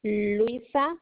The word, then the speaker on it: Luisa: Luisa LUISA Garcia GARCIA.